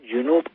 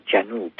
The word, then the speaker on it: جنوب: میگویند جنوب غلط است زیرا این واژه جمع جنب به معنی پهلوست و اگر مقصودمان واژه‌ای است که در مقابل شمال به کار می‌رود باید جنوب یعنی به فتح جیم تلفظ شود. از قضا شمال هم غلط است و تلفظ صحیح آن شمال است.